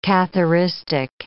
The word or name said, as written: Catharistic